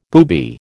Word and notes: booby: American English

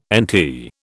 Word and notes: NT: British English

